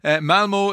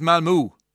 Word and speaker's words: Malmö: Malmo is pronounced Malmö.